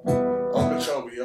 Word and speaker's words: Okeechobee: So if you listen, you'll hear me incorporate energy, rest, peace, and Okeechobee. Did I say that right? – You got it. – Okeechobee, okay, here we go.